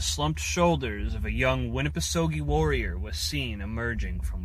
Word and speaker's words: Winnipesaukee: properly pronounced Winnipesaukee.